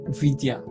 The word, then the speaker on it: Fidia: Fidia. Yes. So can you help me spell that? Um, Fidia is spelled as F I D I A.